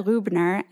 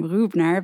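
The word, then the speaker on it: Rübner: Tuvia Rubner, and you'll notice that I just pronounced his name in the German fashion. He's got that fancy umlaut, the two dots over the U, that makes him Rubner, but a typical Israeli wouldn't really be able to pronounce that. So in Israel, he's known as Tuvia Rubner.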